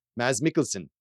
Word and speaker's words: Mads Mikkelsen: And yeah, Wikipedia actually has a recording of him saying it. And he says, Mads Mikkelsen.